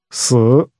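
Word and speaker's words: si (死): si is pronounced with a tone that falls and rises again.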